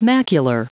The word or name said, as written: macular